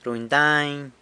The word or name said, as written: runy dany